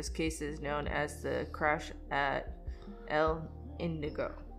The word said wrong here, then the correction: Indio: No. – Indio. – Indio.